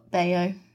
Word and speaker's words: Bayeux: Also, before we start, Mark, one of our listeners pointed out to me that I called the Bayeux tapestry the Bordeaux tapestry last week's episode. No. How ridiculous is that? So who knows what I was thinking. – But it looks like it's spelled as if it's pronounced Bordeaux. – No, it definitely looks like it is spelled Bayeux. – Yeah. Oh, okay. – So I'm just an idiot. So that was just my mistake.